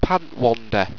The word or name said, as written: pantwander